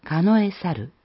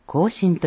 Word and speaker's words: 庚申: 庚申とは、実間十二支、中を組み合わせた年月日の数え方の一つで、かのえ猿、または庚申と読みます。